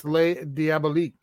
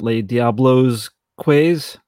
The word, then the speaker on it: Les Diaboliques: It's Les Diaboliques. – Oh, okay. Not Les Diablos Quays? – Con queso? – Or, as they said in the 50s, Les Diaboliques. – Yeah. Hey, everybody. Les Diaboliques.